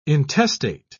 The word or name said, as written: intestate